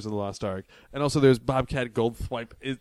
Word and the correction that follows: Goldthwait: Thwite is in it. Thwipe, Swite. – No Goldthwait.